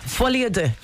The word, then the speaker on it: folie à deux: How do I pronounce it again? – Folie à deux. Joker folie à deux.